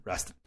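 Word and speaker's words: Rastan: After much discussion as how to pronounce Raston, we came to the conclusion that since it's a Conan the Barbarian clone, the name was riffing off Conan and the gate, and therefore must rhyme with it. Conan Raston.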